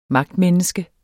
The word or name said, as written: magtmenneske